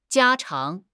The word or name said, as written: 家常